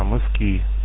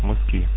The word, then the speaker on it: Мыски: А город звучит у меня не мыски, а мыски. Ударение на последний слог мыски. От слова мыс.